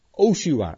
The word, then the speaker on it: Hosea: Hij heette Hosea. Eigenlijk, die haar moet je eigenlijk niet uitspreken. Eigenlijk heette hij in het Hebreeuws Oshua.